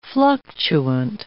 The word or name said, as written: fluctuant